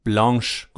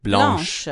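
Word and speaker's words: blanche: مسکولنش هست بلان ولی فمینن بلانش گفته میشه بلانش, بلانش. دقیقا بلانش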